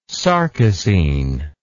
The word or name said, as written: sarcosine